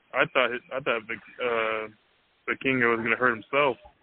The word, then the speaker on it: Vikingo: And Viking, how do you pronounce it? – Vikingo. – Vikingo, yeah. Watching Vikingo and Kenny Omega, I mean, and some of the, his athleticism, it was so quick and crisp.